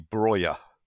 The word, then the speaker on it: Broja: Well, if you listen to the Albanians going nuts on the commentary when he scores for them, if you go with the Albanian pronunciation, it seems to be Broja.